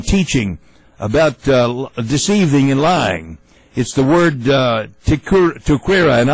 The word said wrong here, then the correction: Taqiyya: The word is, I'll spell it. You all can look it up for yourself. T-A-Q-I-Y-Y-A. Taqiyya. Taqiyya. I broke it down. It's like tack, and the emphasis is on the K.